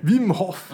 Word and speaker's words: Wim Hof: Wim Hof.